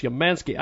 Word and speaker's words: Umansky: And joining us on the telephone is Alex Umansky.